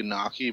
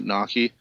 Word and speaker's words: gnocchi: Starts with a G. If it was English, it would be gnocchi, but it's apparently you pronounce it gnocchi.